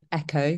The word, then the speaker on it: EQCO: The first one's called Echo, which is spelled EQCO.